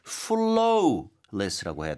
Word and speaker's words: flawless: 발음할 때 그러면 flawless라고 해야 될까요? – 아, W가 있어서 그렇게 생각하실 수도 있겠지만 발음은 flaw.